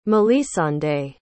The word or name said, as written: mélisande